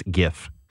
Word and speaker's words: GIF: So someone had because the first words G stands for graphic. Graphic has a hard G. It's GIF. I don't care what the inventor says.